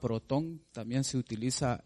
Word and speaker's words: protón: Hay diferentes palabras y una de esas palabras es protón. Protón sería hasta aquí. O proton, ¿verdad? Porque en algunos textos esta O lleva tilde, sería proton.